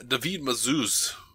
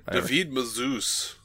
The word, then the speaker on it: David Mazouz: David Mazouz. That's the way I've been told that we have to say his name. – Nah, David Mazouz.